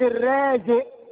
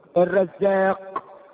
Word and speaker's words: الرزاق: يقول لك الحج عبد الرازق، اسمه الرازق ولا الرازق؟ الرزاق. الرازق، انت عارف الرزق هو ايه؟ المصيبة. إذا اسمه عبد المصيبة، اسمه الرزاق مش الرازق.